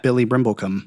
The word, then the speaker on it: Billy Brimblecom: It's just my name, at Billy Brimblecom. – Yeah, it is. At Billy Brimblecom. Oh, Billy. – Spelled like it sounds. Sounds like it spells.